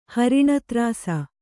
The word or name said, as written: ಹರಿಣತ್ರಾಸ